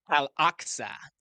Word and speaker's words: Al-Aqsa: Al-Aqsa. – Al-Aqsa Mosque and build the third temple.